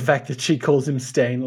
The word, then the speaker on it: Steyn: Or I guess it's pronounced Stain, because Fernanda in all of the chapter misspells it like stain as in like food stain, whereas it's spelt S-T-E-Y-N.